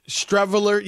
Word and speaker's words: Streveler: And you remember the dude, Stevel. What, how do you say his name? Rob G? Streveler? Yes, Streveler will be the backup.